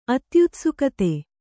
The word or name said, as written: ಅತ್ಯುತ್ಸುಕತೆ